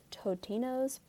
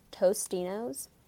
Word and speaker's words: Totino's: One fun fact about me is that I believe I pronounced Totino's, the brand, Tostino's, until I was like probably like 23 years old. I definitely thought the brand was Tostino, but it's not. It's Totinos.